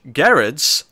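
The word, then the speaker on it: Gerads: We will move on to Mr. Miracle number 9, Tom King, Mitch Gerrards, as I learned this week from his interview. – That's how you say it. – Yes. – Yeah, it's a hard G.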